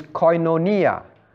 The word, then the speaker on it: koinonia: The word for fellowship in Greek, right, is koinonia. I spell it for you. K-O-I-N-O-N-I-A.